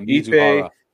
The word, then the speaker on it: Mizuhara: Yeah, Mizuhara.